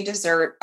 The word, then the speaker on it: Desert: It's spelled desert. We've confirmed they say dessert. It was an argument one day in our car. Anyway, that's how it's said.